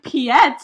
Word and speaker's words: Piet: I've been calling him Piet. – Yeah, you can pronounce it that way, I'm pretty sure.